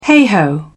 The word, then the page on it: heigh ho: British English